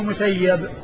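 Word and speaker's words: مسيّب: فكل من جاء على هذه الصيغة فهو مسيب بالياء المشددة، بالياء المشددة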